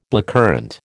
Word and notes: blackcurrant: American English

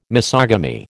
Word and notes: misogamy: American English

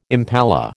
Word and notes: impala: American English

